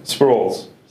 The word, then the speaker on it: Sproles: Sproles.